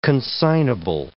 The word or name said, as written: consignable